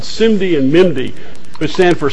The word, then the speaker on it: SIMD: The first, SIMD as it's pronounced,